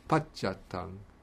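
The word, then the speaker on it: paccattaṃ: And again, in Pali, we don't have this tya sound, which we have in Sanskrit. So tya turns into cha, because it's easier to pronounce. So it becomes pachatang.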